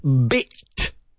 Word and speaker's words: bit: We're going to start with the not-so-important meanings of the word bit, B-I-T. Don't forget, it starts with an explosive B, B, and it ends with another little explosion in the mouth. That explosive English T sound, bit.